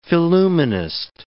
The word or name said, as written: phillumenist